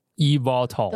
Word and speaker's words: eVTOL: 那看到 aviation 这个字，应该可以想象跟飞行东西有关，所以他做的是，我觉得也是目前最近蛮红，呃，在美国应该也是蛮红的一个主题，就是 EV，呃，我要怎么念这个词呀？eVTOL。